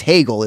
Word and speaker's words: Hegel: like Hegel sounds like a, because I didn't know that's how you pronounce it. And then when I learned, like, it's Hegel is the pronunciation.